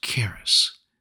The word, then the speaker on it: charis: For you see, the Greek word for grace is pronounced charis, which literally means, quote, that which affords joy, pleasure, delight, goodwill, loving kindness, favor, merciful kindness.